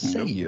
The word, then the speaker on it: Cei-U: It's spelled C-E-I-U. Mm-hmm. You'd think it might be C-U, but it's Seiyu.